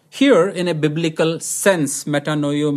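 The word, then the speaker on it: metanoia: The word repentance is pronounced as metanoio in Greek, metanoio.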